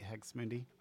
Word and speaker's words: Hex Mundi: Have I been saying that name right, Hex Mundi? – Oh, actually, you're one of the few.